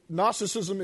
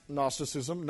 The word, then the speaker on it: Gnosticism: Gnosticism is pronounced Gnosticism, not Gnosticism. It's like Noki is not pronounced Gnoki, right?